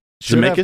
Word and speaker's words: Zemeckis: Okie dokie, Back to the Future, directed by Robert Zemeckis. I believe is how you say that. – Zemeckis.